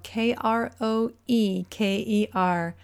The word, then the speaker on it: Kroeker: My last name is spelled K R O E K E R.